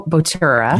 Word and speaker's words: Bottura: And there's also, for example, they just did a feature on this Italian chef, Massimo Bottura. – Yes. – It's spelled Massimo, two S's, I-M-O, Bottura, B-O-T-T-U-R-A.